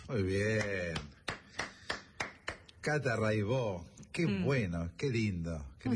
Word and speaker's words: Raibaut: Se escribe Raibaut, métanse, búsquenla, Rai con Y, Baut con B larga. Catalina Raibaut, Raibó se dice.